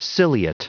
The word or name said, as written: ciliate